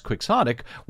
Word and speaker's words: quixotic: Your initial presumption of the pronunciation as quixotic was correct, which sounds like this.